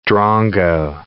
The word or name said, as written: drongo